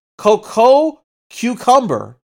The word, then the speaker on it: Coco Cucumber: Coco Cucumber.